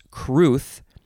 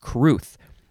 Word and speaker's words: crwth: Next word is pronounced cruth, but it is spelled, these are the best words, C-R-W-T-H.